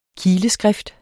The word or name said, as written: kileskrift